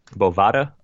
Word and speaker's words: Bovada: I'm not positive on this, but I think it's Bovada. – Bovada? Okay. – It doesn't matter. Going with Bovada. B-O-V-A-D-A.